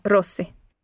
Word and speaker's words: Rossi: S-A-B-A-T-I-N-I. – And Lucia, what's your surname? – Rossi. – How do you spell it? – R-O-S-S-I.